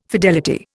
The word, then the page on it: fidelity: Indian